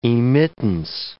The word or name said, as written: emittance